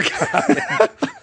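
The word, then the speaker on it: Gaghan: I said this wrong last week, too. What is it, Grierson? – It's Gagan. – Yeah, okay. Well, Gagan. Stephen Gagan.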